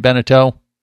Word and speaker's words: Beneteau: But ironically, it sounds like if Santa grants Mike the Mariner's wish and gets him that Beneteau, right? Am I saying it right, Beneteau?